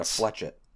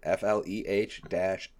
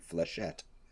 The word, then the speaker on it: flechette: And I imagine it just makes like a thunderous Straight up, I went to Wikipedia. FLEH-SHET. Fleshette.